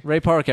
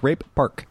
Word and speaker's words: Ray Park: If you say it wrong, it sounds like Rape Park.